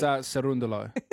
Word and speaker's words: Cerundolo: Going to get through this this clash with Juan Manuel Cerundolo. Cerundolo? – You've pronounced that beautifully. – It's Cerundolo.